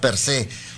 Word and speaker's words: per se: Fíjate, acento en la E, guinda per se.